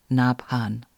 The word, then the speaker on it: Nabhan: Nabhan is spelled N-A-B like bird, H like horse, A-N, Nabhan.